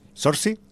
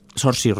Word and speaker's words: Saoirse: ¿Sorsi? – Saoirse Ronan, sí, sería más...